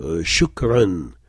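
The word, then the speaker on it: shukran (شكراً): C'est un mot invariable, et ça se prononce « n » à la fin, mais il n'y a pas de « nun ». Lorsqu'on dit « merci » en arabe, tout le monde l'a entendu, « shukran », c'est de cet ordre-là.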